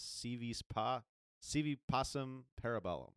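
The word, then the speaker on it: Si Vis Pacem Para Bellum: CV Placim Parabellum. – Close enough. And I'm not fluent in Latin either, but this next album is CV Passim Parabellum.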